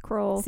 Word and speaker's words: Kroll: Troll rhymes with Kroll.